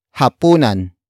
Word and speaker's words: hapunan: And that is hapunan. Hapunan. Hapunan. Hapunan is spelled as H-A-P-U-N-A-N.